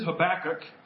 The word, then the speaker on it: Habakkuk: And so the preferred pronunciation is Habakkuk, not Habakkuk or Habakkuk or, you know.